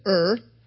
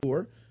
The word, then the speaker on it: Ur: Fourthly, Abraham was from Ur, oftentimes it said Ur, but the Hebrew pronunciation is Ur.